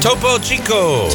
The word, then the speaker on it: Topo Chico: Topo Chico.